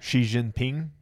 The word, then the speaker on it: Xi Jinping: Well, do you know that the how do you say his name? Zhao Xiaoping, the leader of China, the president of China? – Xi Jinping? – Yeah, that one.